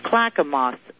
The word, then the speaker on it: Clackamas: It's Clackamas, Oregon. – Mm-hmm. – C as in cat, L-A-C-K-A-M-A-S, Oregon.